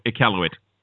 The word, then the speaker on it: Iqaluit: But the council, it's supposed to shoot in Iqaluit. Is that how you pronounce it? – Iqaluit. Iqaluit.